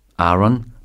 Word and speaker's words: Aaron: Aaron, mit einem langen A und der Betonung auf der ersten Silbe, kam von Vernons Freund Aaron Kennedy.